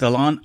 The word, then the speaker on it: Alain: Another great conversation with Alain Hunkins. Alain is spelled L-A-L-A-I-N. It's the French pronunciation.